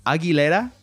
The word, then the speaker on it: Aguilera: By the way, it's Aguilera. – Oh, that's how you pronounce it. Everyone says Aguilera. It's like the whitest thing. It's like what happened when Midwest suburbanites started listening to pop music.